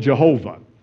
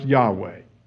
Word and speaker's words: Yahweh: Some used to call it Jehovah. And modern scholars say it should be pronounced Yahweh.